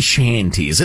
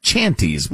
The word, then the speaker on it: shanties: So we were talking about sea shanties. It looks like chanties, but it's pronounced shanties.